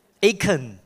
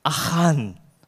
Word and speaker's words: Achan: I think the Americans will pronounce it Achan. Wow, very, very refined, Achan. Cantonese will say Achan, right? Okay? But I think the right Hebrew pronunciation is Achan, okay?